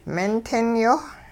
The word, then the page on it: mintingyoh: hupa